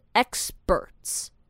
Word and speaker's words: experts: 但是这个 p 我们要把它念成 b 的发音，所以是 experts.